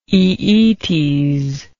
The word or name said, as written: aeetes